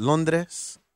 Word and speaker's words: Londres: Of course, the Cubbies are out there doing their thing in Londres. That's how you say it in Spanish.